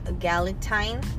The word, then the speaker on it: Galentine: It's uh, we're having a ga- Galentine. Is that how you say it? – Yeah, your Galentine's Day.